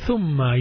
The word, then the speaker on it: ثم: ثاء, ضم on top of the ثاء, so pronounce it ثو, then ميم. Then أشد تشديد on top of the ميم, so he says ثم. تشديد looks like a small W on top of the letter ميم, so we just pronounce it ثم.